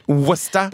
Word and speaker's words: Worcester: It's Worcester, and wise. – By the way. – Worcester. – Worcester. – But it's spelled like Worcester. I know.